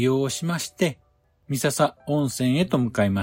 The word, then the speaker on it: 三朝温泉: 三度朝を迎えると元気になると言われる温泉で、そのため三つの朝と書いて三笹温泉と読みます。